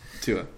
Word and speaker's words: Tua: Tua.